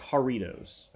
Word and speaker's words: Jarritos: Oh, Jarrito. I think it's pronounced Jarritos. I think it's a J and H J. It's Jarritos.